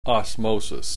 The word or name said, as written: osmosis